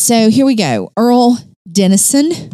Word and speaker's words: Earle: Is that an Earlene? – No, it is not. – Just says Earle. – All right. – E-A-R-L-E, Dennison.